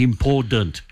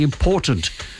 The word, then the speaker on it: important: Do not say important. As if there's a D in the middle. It's wrong, Mr. Prime Minister. Say important.